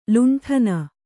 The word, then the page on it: ಲಂಟಾಣ: Kannada